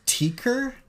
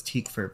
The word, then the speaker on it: Tykwer: I think it's Tykwer. – Tykwer? – Sorry. – No. – It's something like that. – Thank you. – It's a German pronunciation, so I think I've heard it as Tykwer before.